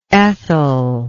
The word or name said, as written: Ethel